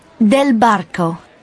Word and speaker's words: Del Barco: Del Barco.